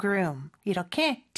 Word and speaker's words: groom: 그 여기서 o가 두 개 있는데 그냥 우우 소리가 아니라 으 소리예요. 그래서 groom 이렇게 발음이 된다는 것도 기억해 주세요.